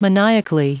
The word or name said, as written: maniacally